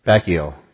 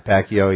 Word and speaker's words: Pacquiao: You might have heard about the stance that the fighter took this week, Manny Pascal or whatever his name is, that fought Mayweather there. I don't even know how to pronounce his. Pacquiao. Yeah, Pacquiao.